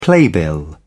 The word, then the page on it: playbill: British English